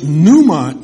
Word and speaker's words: pneuma: In Hebrew, that word is ruach, R-U-A-C-H. And that means wind. It also means spirit. In Greek, the word is pneuma, P-N-E-U-M-A. The P is silent.